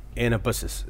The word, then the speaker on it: anabasis: Uh, Anabasis.